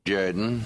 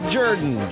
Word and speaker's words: Jordan: It's pronounced Jordan. – Yes. – This is Shug Jordan, football coach of the Auburn Tigers. – It's pronounced Jordan.